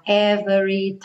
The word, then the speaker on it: every: Então, cuidado para não falar every time. Né? Every, como se fosse do V direto para o R, time, terminando no M.